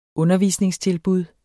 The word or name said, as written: undervisningstilbud